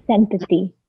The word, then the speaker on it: santati: Um, so, I think a good place to kind of jump back in is a conversation about this this word santati. Am I saying it properly? Yes, santati. Yes. santati. Santati. Ah, it sounds almost like sanctity.